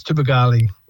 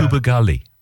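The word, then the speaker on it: Tubowgule: No, no, you're way off. Yeah, but that's okay. It's Tubagali. Yeah. – Tubagali.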